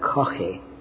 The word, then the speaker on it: Caughey: We would pronounce that Coffey. C-A-U-G-H-E-Y.